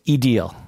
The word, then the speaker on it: Idil: So you spell your name Idil, mm-hmm. – and it's Edil.